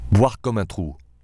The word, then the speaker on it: Boire comme un trou: Boire comme un trou. – Ah. – Again. Perfect pronunciation.